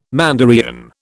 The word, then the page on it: Mandaean: British English